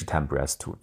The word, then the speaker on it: TMPRSS2: 的一种特殊的蛋白，我们叫 Temprase two 啊，就是 TMPRSS2 啊，这个读音是 Temprase two，这个蛋白质靠它来突破细胞膜。但是 Omicron 非常特殊，它进入细胞膜不需要这个 Temprase two，就我们可以来举例一下，就是前面的变体都要走大门，而这个 Omicron 这个变体呢，可以不走这个正门，它可以从旁边有个小路小门。